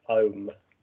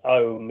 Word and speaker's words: Ogham: No, it's spelt as Ogham, O-G-H-A-M. Mm-hmm. It's actually pronounced Ohm. – Yes, I was given that last year. – It's the Ohm, yeah.